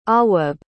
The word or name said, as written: awwab